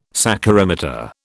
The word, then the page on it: saccharimeter: British English